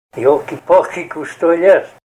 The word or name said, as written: yolkipalki